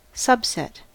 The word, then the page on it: subset: American English